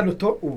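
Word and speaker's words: Doctor Who: Viva Dottor Who, ho detto bene? – Esatto. – Sì, perché non c'è R.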